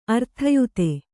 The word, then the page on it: ಅರ್ಥಯುತ: arthayuta